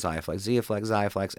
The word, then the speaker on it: Xiaflex: So in my case, so Xiaflex, I think it's pronounced Xiaflex, XIA.